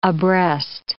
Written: abreast

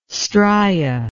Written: stria

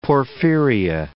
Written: porphyria

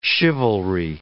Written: chivalry

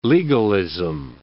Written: legalism

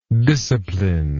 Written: discipliner

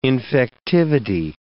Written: infectivity

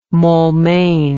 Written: maulmain

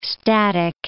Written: static

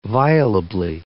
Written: violably

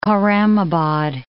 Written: Khorramabad

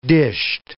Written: dished

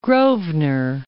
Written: Grosvenor

